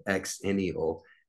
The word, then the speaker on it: Xennial: Now, the reason I'm pronouncing it Xennial, X-E-N-N-I-A-L-S, is because I learned there's another term called Zillennials, Zillennials.